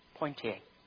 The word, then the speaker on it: Poitiers: Hillary of Poitiers or Poitiers, I don't know how would you pronounce that? Poitiers. Thank you.